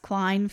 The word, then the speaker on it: Klaehn: They sold to the, I'm going to assume it's pronounced Klein Funeral Home, K-L-A-E-H-N. – Sure. – That seems pretty German to me. – Yeah. – The Klein Funeral Home.